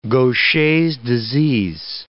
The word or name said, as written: Gaucher's disease